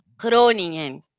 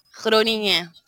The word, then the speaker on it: Groningen: Pois é, em holandês o G tem som de R. Então a cidade se chama Groningen. – E daí Nossa, é o segundo, é o segundo G depois do N não se pronuncia. Fica tipo nha, Groningen, Groningen.